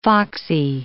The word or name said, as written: foxy